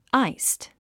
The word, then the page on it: iced: ǽist; アイスド